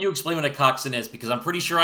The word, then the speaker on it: coxswain: Coxswain. – It is pronounced coxswain. You are correct that it is spelled coxswain, but no, I didn't know that.